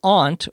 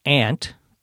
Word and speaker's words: aunt: His aunt, or his aunt, either pronunciation is correct, had quadruplets.